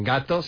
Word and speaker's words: gâteau: Porque gato para nosotros no es un gato, sino un... – Una tarta. – Un pastel. Otra palabra francesa que pronunciamos gato.